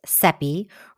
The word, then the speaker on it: Seppi: I'm your host, Seppi, rhymes with Peppy, and today we've got a fabulous guest, Sharon Kelly, who will be talking about financially planning for your refresh.